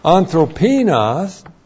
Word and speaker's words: anthropinos: Anthropinos is the key word here.